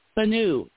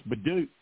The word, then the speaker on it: Bhanu: I don't understand how B-H-A-N-U is pronounced B-H-A-D-U. – Banu. I think it's Banu, isn't it? – Yeah, that's what I said, Banu, but then somebody said, no, it's Badu. Badu. – No, that's it's Banu.